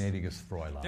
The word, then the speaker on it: Gnädiges Fräulein: Gnädiges Fräulein.